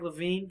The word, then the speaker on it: LaVine: LaVine or Levine? How do you say it? Zach LaVine. – Yeah, it's LaVine.